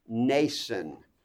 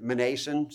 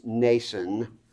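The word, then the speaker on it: Mnason: As they came within sight of Vanity, Greatheart informed them about an old disciple named Nason. I told you last week, I for many years pronounced it Manason, sounded good to me. I believe the right way to pronounce it is Nason. Nason.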